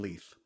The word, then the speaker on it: Leith: The musical Sunshine on the Lay, it's Lay, right? – On Leith. – Leith. Oh, it's Leith, pronounced Leith.